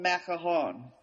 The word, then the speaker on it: macaron: Now, I always call them macaron, and that's the French pronunciation. But I did not know this. The American English pronunciation is actually macaroo.